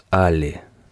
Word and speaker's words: Алли: «Меня зовут Алли», и добавил, пока она не переспросила. «Да, именно с таким странным ударением на первый слог.